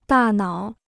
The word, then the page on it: 大脑: dà nǎo